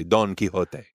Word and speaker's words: Don Quixote: There is a donkey in the movie, Sancho Panza, Don Quixote. – Don Quixote. – I guess I should pronounce it properly, Don Quixote.